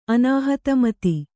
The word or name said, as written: ಅನಾಹತಮತಿ